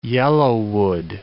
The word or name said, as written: yellowwood